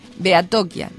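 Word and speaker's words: Behatokia: Bien, así que reiteramos la dirección de correo electrónico, es irache, se escribe I-R-A-T-X-E, @ Beatokia. Beatokia se escribe B larga E-H-A-T-O-K-I-A punto info.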